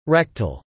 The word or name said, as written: rectal